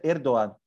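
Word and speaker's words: Erdoğan: Se pronuncia Erdogan. Que se escreve Erdogan.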